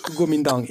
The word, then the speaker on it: Kuomintang: Kuomintang.